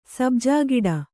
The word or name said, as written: ಸಬ್ಜಾಗಿಡ